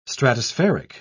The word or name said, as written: stratospheric